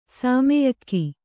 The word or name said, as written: ಶಮ್ಯಾಕ